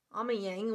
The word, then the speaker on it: Amiens: Amiens, which is not spelled anywhere near how it's pronounced.